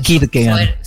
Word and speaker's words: Kierkegaard: Kierkegaard.